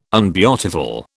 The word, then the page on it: unbeautiful: British English